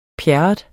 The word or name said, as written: pjerrot